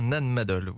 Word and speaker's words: Nan Madol: Et vous allez parler ce matin, de la cité antique de Nan Madol. C'est comme ça qu'on prononce? – Oui, c'est bien Nan Madol.